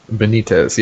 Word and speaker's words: Benitez: Benitez. – Okay. Benitez.